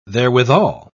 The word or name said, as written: therewithal